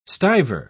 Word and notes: stiver: British English

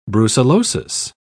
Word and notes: brucellosis: British English

